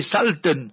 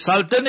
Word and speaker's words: Sultan: They say Sultan. The Sultan is wrong. Sultan. It's an Arabic word. No Sultan business.